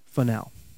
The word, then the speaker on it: Funnell: My name is Bradley Sean Funnell. My last name is spelled F-U-N-N-E-L-L.